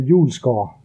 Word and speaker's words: Ulsgaard: Almost correct. Yeah. I'm pronouncing it more like Peter Ulsgaard.